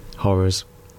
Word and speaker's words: Horrors: And funnily titled that, because I tell you who can't say The Horrors, it's the producer. Yeah. Horrors. Two syllables.